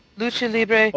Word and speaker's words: Lucha Libre: Lucha Libre.